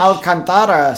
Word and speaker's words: Alcantara: Sandy Alcantara. Am I pronouncing that right? – Yeah. – Looks legit. – Yeah. – Alcantara? Or the Spanish? – Alcantara, sí.